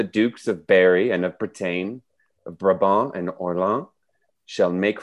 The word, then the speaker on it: Bretagne: And Bretagne, the Dukes of Berry and Bretagne? – Yes, Bretagne, yes.